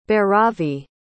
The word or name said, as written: bhairavi